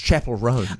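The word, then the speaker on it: Chappell Roan: Chapel Rowan. – Oh, yes. That's pronounced Chapel Roan.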